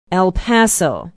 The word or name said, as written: el paso